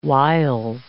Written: whiles

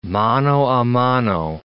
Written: mano a mano

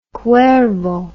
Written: cuervo

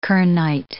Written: kernite

